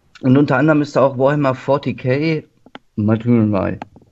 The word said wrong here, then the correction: Martyr: Ich weiß nicht, wie es ausgesprochen wird. – Matter. – Martyr. – Oder? Martyr. – Martyr. Achso.